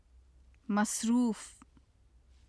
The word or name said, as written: masruf